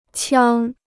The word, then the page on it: 枪: qiāng